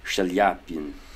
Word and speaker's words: Chaliapin: Turns out it's Chaliapin. Sha rather than cha. The CH must be through French.